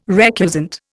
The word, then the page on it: recusant: Indian